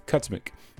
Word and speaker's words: Kocmich: Kocmich. That's K-O-C-M-I-C-H, pronounced Kocmich.